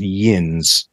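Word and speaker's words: Yinz: And they changed it subtly, but they turned it into a lovable caricature, where Ewens, which is ugly, is pronounced Yins, which has something more of a cosmopolitan ring to it.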